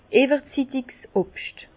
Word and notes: iwwerzittigs Obscht: Bas Rhin; Herrlisheim

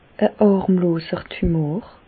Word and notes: e hàrmloser Tumor: Bas-Rhin; Reichshoffen

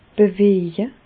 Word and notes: beweje: Bas Rhin; Reichshoffen

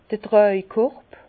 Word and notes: d'r Tràjkorb: Bas-Rhin; Herrlisheim

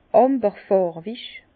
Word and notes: amberfàrwig: Bas-Rhin; Herrlisheim

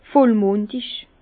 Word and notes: vollmùndig: Bas-Rhin; Herrlisheim

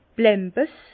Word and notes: Blämbes: Bas-Rhin; Herrlisheim

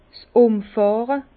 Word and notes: 's Umfàhre: Bas Rhin; Herrlisheim

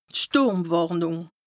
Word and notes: d' Stùrmwàrnùng: Bas-Rhin; Strasbourg

